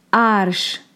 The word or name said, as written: ᶜArš